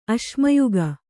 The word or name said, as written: ಅಶ್ಮಯುಗ